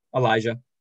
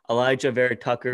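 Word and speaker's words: Elijah Vera-Tucker: Aliyah Vera Tucker from USC. What is it? – Elijah. – Oh, you do pronounce the J. Whatever. Just – copy over it, just redo it. Elijah Vera Tucker, offensive guard from USC.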